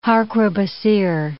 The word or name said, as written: harquebusier